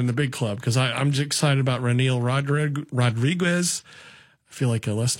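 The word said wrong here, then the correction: Reniel: Renel Rodriguez. There, I said it right.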